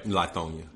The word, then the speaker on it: Lithonia: So you're from Lithonia, Georgia. Hope I'm saying that right. – Lithonia.